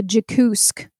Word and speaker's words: Jakoosk: Jakusk. – How do you spell that? – J-A-K-O-O-S-K. Jakusk.